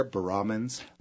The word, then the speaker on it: baramins: So baramins, and you emphasize the second syllable there, baramins.